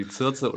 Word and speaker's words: Circe: Genau, also eine Zauberin, äh, hast, hast du ganz genau richtig gesagt, die Circe oder auch Kirke, man weiß es nicht so genau.